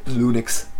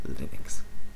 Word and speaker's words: Linux: Linux, Linux, Lunix, everyone's mispronouncing it, giving a rats. Linus doesn't really care about the pronunciation from what he said, but I think it's Linux.